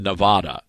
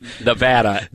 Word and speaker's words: Nevada: Day one, I said Nevada. And the entire phone bank lit up. And I never mispronounced Nevada again after that.